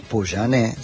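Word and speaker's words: Pouyanné: Hubo una reunión a solas entre el titular de esta compañía francesa, espero pronunciarlo bien, Patrick Poujanet. – Poujanet, sí.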